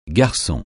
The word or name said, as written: garçon